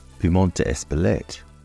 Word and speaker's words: Piment d'Espelette: Espelette pepper, or as it is pronounced in France, Piment d'Espelette, is spelt Piment, P-I-M-E-N-T, d'Espelette, D-E-S-P-L-E-T-T-E.